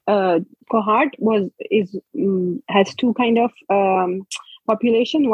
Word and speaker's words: Kohat: Kohat, Kohat, Kohat.